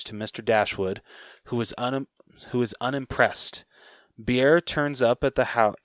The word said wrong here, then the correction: Bhaer: I don't know if I just pronounced his name right. Bear? Wasn't it Bear? B H A E R? – Yeah, sure.